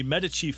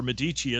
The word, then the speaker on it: Medici: And making all its money in about the 1500s, when the Medici family, or Medici as Americans say,